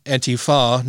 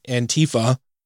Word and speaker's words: Antifa: Antifa, pronounced Antifa, not Antifa, is short for anti-fascist action.